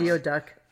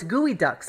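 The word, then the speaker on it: geoduck: Slow geo duck. – It looks very. – Is it gooey? Oh, it's gooey duck. Slow gooey duck, okay, right? That's how you pronounce that.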